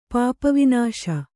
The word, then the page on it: ಪಾಪವಿನಾಶ: Kannada